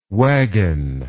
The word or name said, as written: waggon